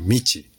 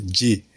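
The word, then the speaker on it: ji (路): So someone say it's a path of dreams, but to me it's something more, because the second character, path, have multiple pronunciations and is usually pronounced lo or michi. To read this character, ji, is very unique. There are only few words where it's pronounced ji.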